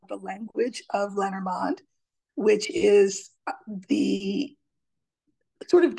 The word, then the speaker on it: Lenormand: Lenormand would be the French pronunciation, but we say Lenormand, we say Lenormand.